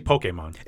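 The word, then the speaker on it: Pokémon: How do you pronounce Pokemon? – It's actually Pokemon. – It is. I was going to say Pokemon. Because there's a little thing over the E.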